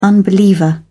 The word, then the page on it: unbeliever: British English